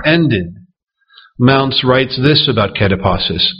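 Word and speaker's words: katapausis: The word rest here is translated from the Greek word kataposis.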